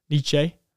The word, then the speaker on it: Nietzsche: It's a famous quote by Nietzsche. I pronounced his name correctly, Nietzsche.